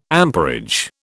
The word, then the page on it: amperage: British English